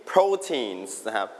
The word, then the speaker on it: protein: Meat, dairy, beans, eggs, all of these things, you can mainly find them in proteins. Proteins. Everybody repeat after me, proteins. One more time, proteins. คำนี้ออกเสียงว่า protein เน้นที่พยางค์แรกครับ proteins.